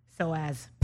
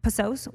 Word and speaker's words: psoas: Tell me how to pronounce this word. P-S-O-A-S. – So as. – Post. psoas? What is it? – Psoas. It's a muscle.